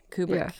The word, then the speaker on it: Kubrick: Kubrick? – Kubrick, yeah.